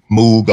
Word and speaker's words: Moog: That jury is still out on which way you're supposed to pronounce that Moog or Moog, but you know, right. I think the proper pronunciation is Moog, like rogue.